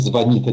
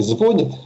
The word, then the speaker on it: звонит: Но, скажем, мы до сих пор, образованные люди, во всяком случае, говорим «звонит», а не «звонит», хотя большинство глаголов такого типа на «звоню», «звонит» уже сдвинули ударение в сторону «звоню», «звонит». Да, но вот «звоню», «звонит» держится, да, потому что школа это блокирует.